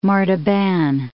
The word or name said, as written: Martaban, Gulf of